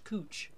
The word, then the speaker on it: Couch: Like there's a street in Portland that is spelled C O U C H. Now I'm going to give the audience the tens of people that are listening to this podcast. – Yeah. – How would you pronounce the word C O U C H? Go ahead, talk amongst yourselves. – Mm-hmm. – No, it's pronounced cooch.